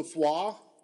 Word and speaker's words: Foix: Sunday, stage 15, Limoux to Foix. I know OUX or OIX, that's how you pronounce it. That's the only thing I know about French. – Well, because it's like a roux.